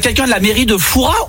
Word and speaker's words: Fouras: Eh bien écoutez, on dit Fouras. On ne prononce pas le S. – Et donc on dit bien Père Fouras. – Voilà, tout à fait.